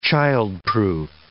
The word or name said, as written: childproof